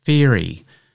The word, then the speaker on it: feirie: And this week's Word of the Week is Feary, and that is spelt F-E-I-R-I-E.